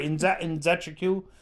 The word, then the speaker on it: Nzechukwu: I learned it before. Nzechukwu? – Yeah, that sounds right. Nzechukwu.